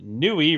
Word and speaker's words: Nui: Lord Terminal writes in, Nui rhymes with Yui and is also voiced by Stephanie Shea.